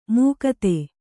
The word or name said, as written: ಮುಕ್ತೆ